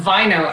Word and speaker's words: Vaino: It is not. It's Elspeth Vaino, actually. I always say, you'll remember this, rhymes with wino.